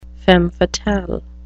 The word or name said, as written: femme fatale